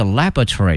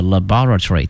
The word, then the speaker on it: laboratory: 那么实验室呢有两个发音，一个是 laboratory，一个是 laboratory 都可以。